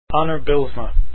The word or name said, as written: Bylsma